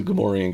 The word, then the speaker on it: Gamorrean: I talk about Gamorrean cards. – Actually, I just found out that's how it's pronounced, but I know full well who they are.